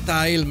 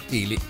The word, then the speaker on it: Thiele: Chris Thiele si scrive Thiele, ma non si pronuncia Tile ma Thiele, almeno così dicono certi che se ne intendono, vai a sapere.